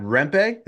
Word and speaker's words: Rempe: I do have to ask you, because I'm sure Rangers fans after the game, probably even during the game, Matt Rempe, is that how it's pronounced? – Yeah.